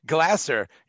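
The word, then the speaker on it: Glaser: Was that John Paul Glazer? – Of course. John Paul Glazer, yeah. – John Paul Glazer? – Are we getting his name right? – Glasser. Yeah, Glasser. Paul Glasser. Yeah, yeah.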